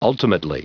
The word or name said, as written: ultimately